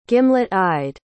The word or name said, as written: gimlet-eyed